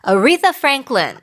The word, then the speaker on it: Aretha Franklin: Aretha Franklin.